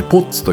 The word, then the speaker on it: POTS: POTS と書いてポッツと読むんですけどもね私もその構成をさせていただきながら随分とこのポッツに関してはね、